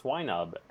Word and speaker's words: Swinub: So I looked up the pronunciation, and according to the anime at least, it's Swinub and Pilo Swine.